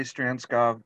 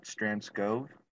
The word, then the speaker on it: Strandskov: Well, most people say Strandskov, but my family pronounces it Strandskov. – Strandskov. – It's actually a Danish name, Strand. – Oh, it's Strand Dan. And Skov.